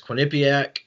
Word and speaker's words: Quinnipiac: Out in Greenville, East Carolina will face Quinnipiac.